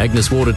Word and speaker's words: Agnes Water: Heading down to Agnes Waters. – Mate, it's pronounced Agnes Water.